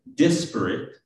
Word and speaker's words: disparate: Its adjective, disparate, accented on the first syllable, of course, dis, right, is often used to emphasize strong differences.